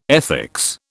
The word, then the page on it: ethics: British English